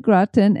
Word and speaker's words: Groton: As for the pronunciation of Groton, it is pronounced just like Groton, as in au gratin potatoes.